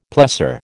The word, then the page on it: plessor: American English